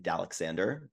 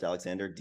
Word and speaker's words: Dalecsander: So I am, funny story, I pronounce my username Dalexander because it's supposed to be my initials DAC and then my middle name Alexander. Did not realize it's spelled Dale C. Sander, and could not figure out why people were calling me Dale for the longest time.